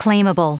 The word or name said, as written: claimable